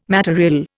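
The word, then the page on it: matériel: Indian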